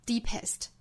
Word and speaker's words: deepest: Deepest 不要发成 deepest 我知道很多同学把 EST 还是读成紧音不对应该是 est deepest Only if you've been in the deepest valley V-A-L-L-E-Y